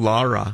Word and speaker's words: LARA: How do I want to pronounce that? – Yeah, it's called LARA.